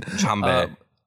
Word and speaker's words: Jambe: I love the reveal of Sanji's cool fucking fire leg technique, Diable Jambre, or I can't pronounce it. – Jambe.